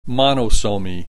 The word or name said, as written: monosomy